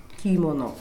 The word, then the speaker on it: kimono: Kimono. – Usually Western people put accent first, kimono. But kimono is not the correct pronunciation. We Japanese people pronounce it in a very flat manner.